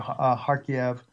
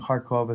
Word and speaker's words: Kharkiv: And as I'm watching in the latest major losses that Putin has had, specifically a Kharkiv, that's actually the way they pronounce the name. I think Kharkov is how we probably pronounce the name, where the Ukrainians were able to take back that city and the Russians retreated from it.